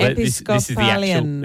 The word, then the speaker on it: Episcopalian: Episcopalium. – Okay. – Episcopalian. – Episcopalian. This is the actual pronunciation.